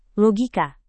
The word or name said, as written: logika